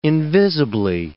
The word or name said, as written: invisibly